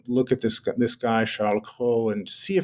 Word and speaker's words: Cros: Cros. That's Cros. Some people mistakenly hear Cheryl Crow, and very different story.